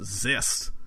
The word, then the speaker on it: Xyst: X-Y-S-T. Zest. Which I'm assuming is pronounced Zest.